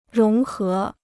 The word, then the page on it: 融合: róng hé